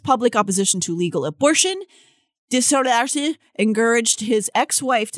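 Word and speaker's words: DesJarlais: I don't know how to say his last name. – Dejaris. – Yeah. Dejarlace.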